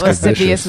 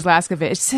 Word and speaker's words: lascivious: I thought Lascivious was Laskavicious.